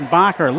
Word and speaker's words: Bacher: That his name is pronounced Bacher. Looks like Baker, but it's Bacher with his first foul and the team's second.